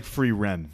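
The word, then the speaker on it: Frieren: Furin? – It's like free ren. – Free ren? Oh. I was like, Furin.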